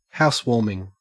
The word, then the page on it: housewarming: Australian English